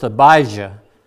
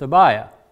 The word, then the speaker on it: Abijah: and it was pronounced Abijah with a J, Abijah. In some respects, but in the Gospels, it's pronounced Abiah.